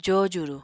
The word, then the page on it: jojuru: Apatani